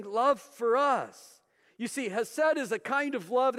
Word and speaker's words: hesed: It's a word that's pronounced hesed. Say hesed with me.